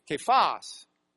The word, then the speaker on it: Kephas: In the Greek, it'd be Kephas. Actually, they'd put a little lilt at the end. It'd be Kephas. So Kephas, They didn't have a soft C like sa, sa. So it's kephas in the Greek is just Greek for the Aramaic kaf.